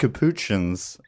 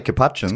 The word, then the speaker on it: capuchin: The white-faced capuchins. – Can you stop saying it like that? – How do you say capuchins? – Capuchin. – Capuchin. That is, yeah, because it's. I've looked this up before.